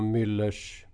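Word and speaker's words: Mueller: Kan du lägga ut texten angående det här med impeachment som man pratar om och Müllers... Muellers? Hur uttalar man hans namn? Muellers? – Vill du få uttala det hur du vill. – Müller ska jag säga. – Ja, Müller då.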